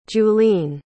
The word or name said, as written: juleen